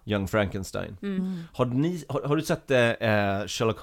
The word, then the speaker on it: Frankenstein: Frankenstein. You're putting me on. No, it's pronounced Frankenstein. Do you also say Frederick? No, Frederick. Well, why isn't it Frederick Frankenstein? It isn't. It's Frederick Frankenstein.